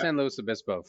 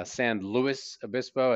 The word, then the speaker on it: San Luis Obispo: R-O-B-L-E-S. The locals say Paso Robles, which is a real cringe to the tongue. San Luis Obispo, as opposed to San Luis Obispo. There are some local pronunciations that will really strain your Spanish ear.